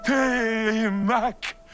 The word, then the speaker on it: Télémaque: Télémaque! Je prononce comme il faut? – Oui, c'est parfait.